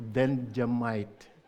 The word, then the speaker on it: Benjamite: It's Benjamite, not Vegemite, as we might say.